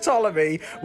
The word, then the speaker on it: Ptolemy: Ptolemy the 13th, Pharaoh of Egypt, it's pronounced Ptolemy, were played by Harry Hazmat Perber, and introducing Lara Larry Bradburn as Cleopatra.